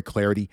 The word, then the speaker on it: Clardy: You can follow me on Twitter at Troy Clarity. The last name is spelled C-L-A-R-D-Y.